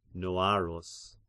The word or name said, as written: Noarus